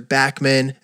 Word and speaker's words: Backman: You can see Spencer's work on Instagram at Spencer Backman, and that's spelled B-A-C-K-M-A-N, just how it sounds.